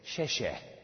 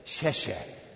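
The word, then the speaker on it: xie xie (谢谢): In fact, in Mandarin, this is how you say thank you. You say, xie xie. Say that with me. Xie xie.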